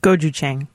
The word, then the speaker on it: gochujang: How do you pronounce this next one? Gochujang? – Gochujang.